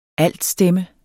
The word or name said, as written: ALTSTEMMEN